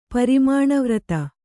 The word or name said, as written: ಪರಿಮಾಣವ್ರತ